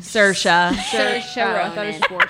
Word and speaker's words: Saoirse Ronan: Saoirse Ronan. – Saoirse Ronan.